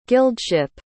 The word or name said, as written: guildship